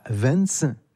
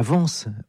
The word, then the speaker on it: Vence: 1930 à Vence, non, mauvaise prononciation, à Vence, autre ville d'artistes dans les Alpes-Maritimes.